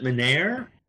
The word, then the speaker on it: Menare: We pronounce it Menard.